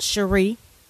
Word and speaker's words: Sherri: I was given the name Cherie by my mother, and even though it's spelled Sherry, S-H-E-R-R-I, it's pronounced Cherie.